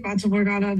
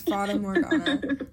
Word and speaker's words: Fata Morgana: So your new album, Fata Morgana, am I saying that right? – I say Fata Morgana, but I also don't speak Italian, so what do I know? – I don't know.